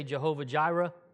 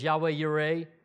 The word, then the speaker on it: Yahweh Yireh: We always say Jehovah Jireh, but it's Yahweh Yireh is how it's pronounced closely anyway, not my Hebrew fluent.